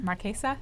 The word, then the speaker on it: Marchesa: Marchesa, is that how you pronounce it? – Yeah.